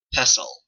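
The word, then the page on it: pestle: Canadian English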